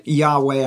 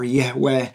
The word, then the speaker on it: Yahweh: When scholars finally, much, much later, figure out what was going on, uh, they guess that the name was probably pronounced Yahweh. I'm not sure exactly how they came to that conclusion, or Yahweh.